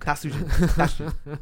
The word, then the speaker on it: Tatsujin: Um, Taiko not uh, ta- Taiko no ta- Tatsujin two game combo. – My inner weeb's like you're saying it wrong. Tatsujin.